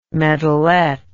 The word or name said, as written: medalet